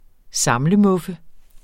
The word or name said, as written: samlemuffe